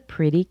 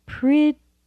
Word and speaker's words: pretty: the pretty candles. The pretty candles. Notice pretty, pretty, pretty. The first syllable has the primary stress. Pretty. And notice that T in pretty is softened to a D.